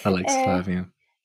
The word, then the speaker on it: alexithymia: And maybe, I don't know how to pronounce that, alexithymia? – Yeah. – Yeah. – Alexithymia.